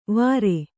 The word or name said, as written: ವಾರೆ